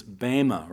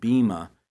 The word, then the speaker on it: bema: The judgment seat, the original word is bema, or sometimes pronounced bema.